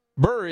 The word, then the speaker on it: Buhr: Paul Burr, I believe is how it's pronounced, last name, B-U-H-R, Burr of Viroqua, to replace William Bruins, whose term expired at the end of 2022.